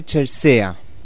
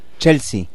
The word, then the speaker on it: Chelsea: Te recuerdo claramente en el, en el Hotel Chelsea. ¿Se pronuncia así? No. Chelsea. Chelsea. Chelsea, perdón.